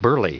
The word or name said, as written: burly